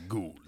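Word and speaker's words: Goa'uld: Yeah, you said. Gould instead of Gould. – I long ago I decided to be General Hammond on this situation. Yeah. Just say Gould. – Gould. Yeah. Gould.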